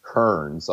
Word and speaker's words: Kerens: And she figured out who my biological father was from my matches, and it was a man named Emmett Kearns, although it's spelled K-E-R-E-N-S.